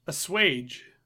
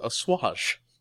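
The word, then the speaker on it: assuage: I apparently had never heard that word spoken before and just read it. – Mm-hmm. – So I always just thought it was called... It was the way you pronounced it was a swash. Which... is wrong.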